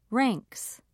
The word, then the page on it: ranks: British English